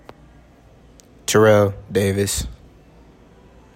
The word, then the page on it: Terrell Davis: tih REL DAY viss